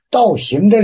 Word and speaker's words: 道行: 道行这俩字，这个词念道行。 – 道行啊，对。 – 道行就是这个，就是这个。就读作道行，不是道行。